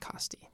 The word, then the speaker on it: Costi: Costihin, I should say. I think it's pronounced Costi.